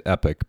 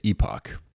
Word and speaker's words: Epoch: I think they say it Epic, but I say it Epoch. – Well, that's how they spell it, Epoch. – Yeah, Epic is E-P-I-C, not E-P-O-C-H. But I know there are different ways that people want to pronounce that. – I think both are acceptable.